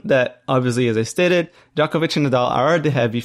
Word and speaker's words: Djokovic: Well, Djokovic, I think I've been corrected on Twitter, and I've listened to people talk, and I've listened on Wikipedia. His name is pronounced Djokovic.